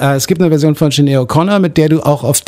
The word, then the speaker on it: Sinéad O'Connor: Queen of Denmark gibt es dann auch noch in der Version von Sinéad O'Connor, ich glaube, so spricht man sie aus.